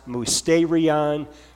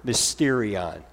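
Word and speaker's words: mysterion (μυστήριον): Well, we say that because both the word sacrament and mystery are derived from the same Greek word, pronounced mysterion, or some people say it a little bit different, mysterion. You could pronounce it either way.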